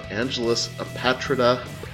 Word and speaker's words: Angelus Apatrida: They are Angelus Apatrida. Am I saying that right? – Perfectly right.